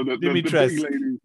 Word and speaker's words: Dimitrescu: So Dimitrescu.